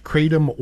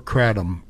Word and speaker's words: kratom: It can be pronounced kratom or kratom.